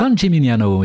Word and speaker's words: San Gimignano: Donc un nouveau chapitre de ce livre, La fille de San Gimignano, si je prononce bien, c'est ça ? – San Gimignano, oui.